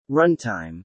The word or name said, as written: runtime